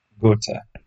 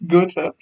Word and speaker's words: Goethe: GOTA. Goethe. – Goethe Institute. Which is a similar thing for German. – No. Not yet, no, on the podcast, I'm not letting it pass. It's GOTA, please.